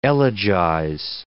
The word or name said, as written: elegize